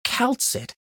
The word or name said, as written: Kal'tsit